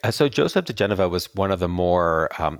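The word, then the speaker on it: diGenova: Yeah, Joseph DeGeneva.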